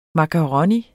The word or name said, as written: MAKARONIER